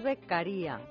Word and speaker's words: Beccaria: No, no, es Beccaria. – Beccaria. – Sí, porque es, es, italiano y mm, en el DNI no lleva tilde. Porque, bueno, a, a, la española tendría que llevar tilde, pero como es italiano, se escribe a la italiana y se pronuncia a la italiana.